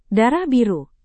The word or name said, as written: darah biru